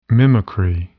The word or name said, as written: mimicry